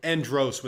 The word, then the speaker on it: Andros: Andres. That's an Andre. How do you say it? – Andros with an O. It's kind of like Davos, like Game of Thronesian.